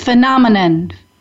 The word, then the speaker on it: phenomenon: And I just want to correct your pronunciation. It's phenomenon.